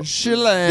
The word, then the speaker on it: Chiellini: Giorgino Chiellini. – So Chiellini.